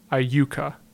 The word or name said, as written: Iuka